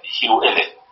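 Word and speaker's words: Whewell: این تلفظ دقیقش یو ال با حمزه مثلا.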